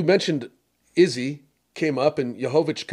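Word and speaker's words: Błachowicz: If you're Jan, and I think they pronounce his name Wachowicz, looked like with a W. Or Wachowicz.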